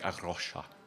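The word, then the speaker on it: A Rocha: You have to do this great rolling R, like Arosha.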